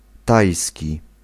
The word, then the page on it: Thai: /ˈtaɪ/